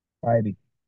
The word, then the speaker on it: Bybee: Is it Baby? – Bybee. – Bybee. Okay, thank you.